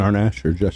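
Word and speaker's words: Amash: Yeah, Justin Arnash.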